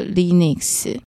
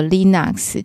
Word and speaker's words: Linux: 用这个 Linux 或 Linux 这样发音好了，然后它有很多不同的版本嘛，在早期就是大家对这些系统都很不熟悉，所以会操作这些系统的人，哇，我们大家都觉得好厉害。